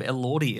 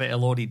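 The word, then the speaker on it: Elordi: Oh, Lordy Elordy, the Jacob Elordy incident.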